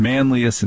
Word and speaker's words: Manlius: when you go out to DeWitt and Manlius, which is how they pronounce it, DeWitt and Manlius and Skinny Atlas, most of those are terrifying.